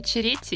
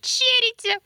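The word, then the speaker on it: Ceretti: я сказала Витория Черетти, и я мне спустя блин, 4 месяца. Такой, правильно говорить, черите.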